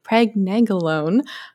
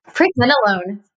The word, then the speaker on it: pregnenolone: Pregnenolone. Did I say that right? Pregnenolone. Pregnenolone. Okay, I knew I was saying that wrong.